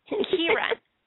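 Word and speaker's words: Ciarán: C-I-A-R-A with the accent N. Hins. And I cannot pronounce his name because I do not know if it's a hard C or a soft C. So forgive me. So if someone can tell me. Oh, Kiara, thank you. I knew she would be able to tell me. It's Kieran. Kieran, I knew that.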